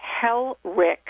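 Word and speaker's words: Helfrich: Paul Helrich, and that's a CH at the end, .com.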